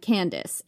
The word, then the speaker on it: Candace: Strabo calls her the One-Eyed Candace, and he spells it like Latinized, C-A-N-D-A-C-E, which is why I'm pronouncing it that way.